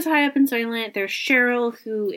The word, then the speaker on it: Shirl: Yeah, so it's in the investigation that he meets Cheryl, whose name is spelt in a kind of bananas way, S-H-I-R-L, Cheryl. – But it is pronounced Cheryl.